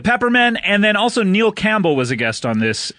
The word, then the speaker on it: Campbell: It's written like Camp Town Ladies. – Right. – But a bell instead of Town Ladies. – Right. – Yeah. – That's how I remember how to spell it, for sure. – Right, but it's Campbell.